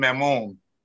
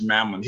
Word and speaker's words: Maimon: It's called Twilight in Hazard by a guy named Alain Mamon. That may not be the way you pronounce it, but that would be the way you pronounce it in French. M-A-I-M-O-N. It's probably pronounced Mammon.